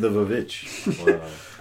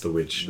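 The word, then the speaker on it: The VVitch: Yeah, it's the witch from the Vavitch. I know it's pronounced the witch. – The Vavitch. – I think that everyone knows this. – But it's stylized the Vavitch.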